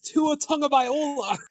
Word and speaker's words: Tua Tagovailoa: He butchered the name! It's Tua Tagovailoa.